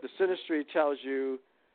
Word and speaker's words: synastry: It's called synastry.